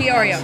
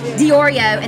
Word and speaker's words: Diorio: How do you say his last name? – Diorio. – Diorio, and then Mary Murphy.